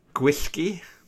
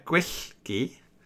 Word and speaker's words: Gwyllgi: How do you pronounce that? – Gwyllgi. – Okay. – Yeah, Gwyllgi. Easy. It's okay. The black dog of fate. – Gwyllgi. It's exactly. Just pronounce it as it's written.